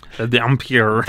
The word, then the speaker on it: dhampir: But, so, vampire is an Albanian word, and it's, I don't even know how to say this, D-H Dampir? – Dampir. – Dampir. D-H-A-M-P-I-R.